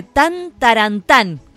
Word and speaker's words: tan tarantán: Dice, tan tarantán, con acento en la última A, y te ponen un tamborcito, ¿no?